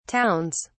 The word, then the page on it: towns: British English